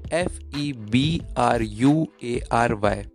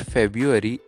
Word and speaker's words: February: Second word is F E B R U A R Y. Many of us says it February, but it's not February, it's February. February.